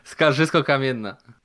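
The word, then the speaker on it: Skarżysko-Kamienna: Skarżysko Kamienna.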